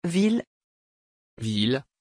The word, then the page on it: ville: French